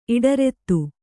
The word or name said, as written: ಇಡರೆತ್ತು